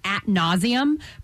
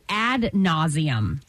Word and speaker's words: ad nauseam: I thought it was like you would say, you would talk about something at nauseam, but it's actually ad nauseam.